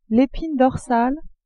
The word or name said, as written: epine dorsale